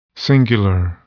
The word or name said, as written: singular